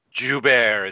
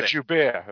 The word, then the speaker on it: Gerbert: We're all the main characters in the present, so we get to see Knox and Gerbo, I think that's how you pronounce his name. – Joubert is how they say it. – Yeah, Joubert, that's right.